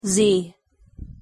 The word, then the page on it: z: English